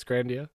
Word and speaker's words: Grandia: Grandia 2? – Okay, yeah, it's Grandia.